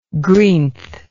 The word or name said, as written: greenth